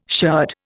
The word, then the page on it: sherd: Indian